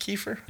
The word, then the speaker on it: kefir: Is it pronounced kefir? – Yeah. – Oh, okay.